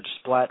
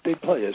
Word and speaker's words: Desplat: Well, I really am uncertain about this, but I'm going to give it to I'm going to root for Desplat, isn't it? – Desplat, yeah. – No, I don't think it's Desplat. I think the T is silent.